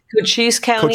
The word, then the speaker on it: Cochise: And then the Secretary of State, your opponent, issued threats of legal action if, for example, one of the counties, is it pronounced? – Right. Cochise County.